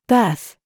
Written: THINK

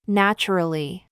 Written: TRAP

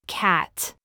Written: TRAP

